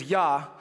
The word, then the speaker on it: hallelujah: It does mean praise the Lord, and the portion of the word for Lord is Yah, so we pronounce the J as a Y, and Yah is seen many times in Old Testament Hebrew names like Elijah, and in English we hit the J, and Elijah, for what it's worth, is a great name for a firstborn son.